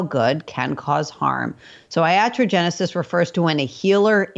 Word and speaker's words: iatrogenesis: And you use a Greek phrase, atrogenesis. Is that how you pronounce it? – Iatrogenesis. Yeah,